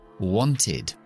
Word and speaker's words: wanted: Entonces vamos a pronunciar con la id wanted.